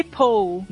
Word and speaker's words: pull: o pull. pull. Aí não é pull. Aí não é pull. – É, pra não confundir com piscina, né? Que é pool. – Nossa, vamos começar mesmo. é, ele é um som mais curto.